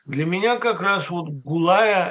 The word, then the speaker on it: Гулая: Вот судьба Ирины Гулая, понимаете? Это же, некоторые произносят Гулая, ну, конечно, Гулая.